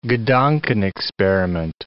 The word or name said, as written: gedankenexperiment